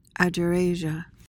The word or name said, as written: agerasia